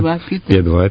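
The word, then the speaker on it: Пе-2: И в самый сложный период, 41-42 годы, дал фронту, я себе выписал, 730 пикирующих бомбардировщиков П-2 и 134 дальних истребителей П-3. Правильно я произношу? – Правильно. П-2, П-3, правильно.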